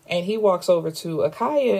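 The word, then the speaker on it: Akhalia: Akaya? Or Akylie? Akylia? – It's A-K-H-A-L-I-A. Akila. I think that's what I was going with.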